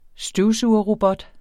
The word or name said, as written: støvsugerrobot